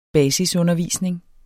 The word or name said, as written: basisundervisning